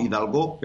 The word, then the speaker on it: Hidalgo: el seleccionador se llama Michel Hidalgo. Allí lo pronuncian siempre acentuando la O, Hidalgo, pero bueno, Hidalgo es apellido español, de origen español, y ella es de origen gaditano.